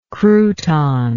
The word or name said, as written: crouton